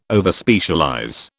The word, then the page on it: Overspecialize: British English